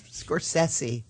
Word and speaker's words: Scorsese: No, she's Scorsese. Oh. Correct pronunciation.